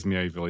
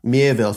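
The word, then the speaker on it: Miéville: So we've got The Bright Sword by Lev Grossman and The Book of Elsewhere by Keanu Reeves and China Mieville, is that how you pronounce his last name? – Yeah, I think so. I think he's pretty forgiving on how to pronounce it, but I think it is Mieville, yeah.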